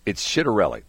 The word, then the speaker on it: Schittarelli: That's his name because it's spelled like the Italian C where it's an S. It's Schittarelli.